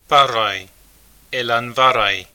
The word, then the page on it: Barraigh Eilean Bharraigh: [ˈparˠaj]; [ˈelan ˈvarˠaj]; Scottish Gaelic